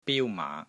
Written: BIU1 MAA5

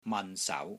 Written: MAN6 SAU2

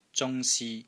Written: zung1si1

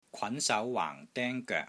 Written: KWAN2 SAU2 WAANG4 DENG1 GOEK3

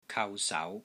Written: KAU3 SAU2

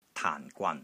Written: TAAN4 GWAN3

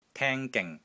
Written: TING3 GING3

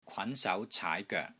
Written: KWAN2 SAU2 CAAI2 GOEK3

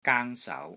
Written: GANG1 SAU2

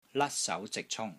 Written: LAT1 SAU2 ZIK6 CUNG1

